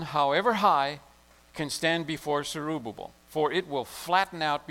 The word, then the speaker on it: Zerubbabel: Then the angel said, "This is God's message to Zerubbabel." Sorry, I had that name wrong. It's Zerubbabel.